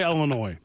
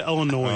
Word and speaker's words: Illinois: The S is silent at the end of Illinois. I don't know why people You just say Illinois. Don't say Illinois.